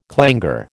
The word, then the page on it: clangour: American English